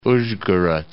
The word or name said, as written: Uzhgorod